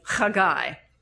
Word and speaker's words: Haggai: Or if you want to speak it in terms of Hebrew pronunciation, it's Haggai.